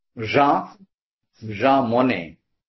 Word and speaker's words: Jean Monnet: Jean Monnet.